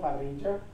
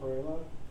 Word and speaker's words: parrilla: It's called parrilla. But you guys pronounce it parrilla.